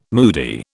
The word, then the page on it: moody: British English